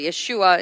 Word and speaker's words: Yeshua: Jesus in English, of course, but Yeshua in his original pronunciation, which means the Savior.